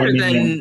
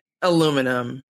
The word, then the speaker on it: aluminium: It's so much better aluminium than aluminum. – Yeah. – You know, every time I realize that the Brits say aluminium, or I guess in this case, Australians too, I'm just bowled over. – Aluminium laboratory. – Yes. – It's like putting the accent on a different syllable.